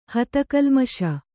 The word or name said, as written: ಹತಕಲ್ಮಷ